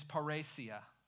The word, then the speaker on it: parrhesia: The Greek word is paresia. Paresia. Really, you want me to spell it? P-A-R-R-H-E-S-I-A.